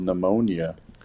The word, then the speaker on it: pneumonia: Very proud because in sixth grade, I was in a spelling bee and I spelled pneumonia incorrectly. – Oh, that P will get you back. – Silent. – To go from sixth grade, the devastation of forgetting the silent P.